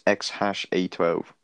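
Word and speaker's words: X Æ A-12: No, it's XAEA12, but the AE is pronounced hash. – So his name is what? – No. Yeah, it's pronounced hash, so it's XHASH12.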